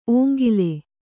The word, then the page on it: ಓಂಗಿಲೆ: Kannada